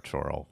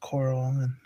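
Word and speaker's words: Chorrol: Or Choral, whatever you want to call it. – I heard it pronounced Coral in the city. So I'm going with Coral.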